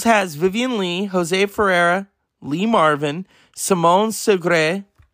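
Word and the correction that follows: Signoret: Is that how you say her name? I always said her name wrong. She was in that movie Dobelik. Let's see. Won an Oscar for was it Separate Tables? Okay. Very, very great French actress. – Simone Signore.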